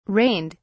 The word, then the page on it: rained: British English